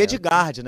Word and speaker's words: Edgard: Se escreve é, Edgard, na verdade.